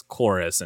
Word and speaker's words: Chorus: I know we weren't sure quite how to say it because it looked like it was a Chorvs. But the official pronunciation is Chorus, and it's supposed to be a U. I don't know why it looks like a V.